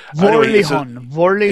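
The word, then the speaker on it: Vorilhon: Can you pronounce that better than me? Hang on a second, let me find it. – Verilihon. – Verilihon. – Exactly.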